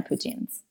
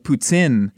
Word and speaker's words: poutine: The options are endless, but I am more of a traditionalist when it comes to my poutines.